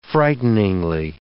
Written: frighteningly

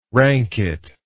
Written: ranket

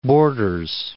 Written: Borders

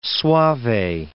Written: Soave

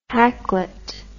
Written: hacklet

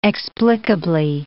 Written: explicably

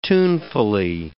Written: tunefully

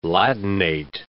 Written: Latinate

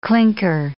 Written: clinker

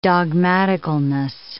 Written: dogmaticalness

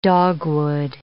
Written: dogwood

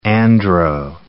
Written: andro

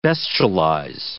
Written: bestialize

